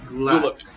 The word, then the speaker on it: Gulak: And then, of course, Matt Riddle versus Drew Gulak, or Gaelic, however you say his name. Gulak. Never mind. Yes, Gulak. Gulak.